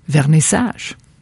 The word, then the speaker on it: vernissage: And there is also Clara Jane in the first book, Still Life, has what we call in Quebec a vernissage, and that is the first night of an art show, and that's how you pronounce it, vernissage.